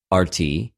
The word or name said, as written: ἀρτι